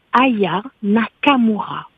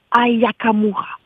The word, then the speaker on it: Aya Nakamura: C'est Aya Nakamura. Ce n'est pas Aya Kamura.